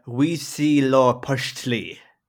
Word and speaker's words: Huitzilopochtli: I think there's actually a pronunciation for it there, which is Huitzilopochtli.